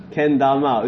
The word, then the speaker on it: Kendama: I thought it's spelled with K. Then why is pronounced as Ken? – K-E-N. – Oh, okay. Kendama.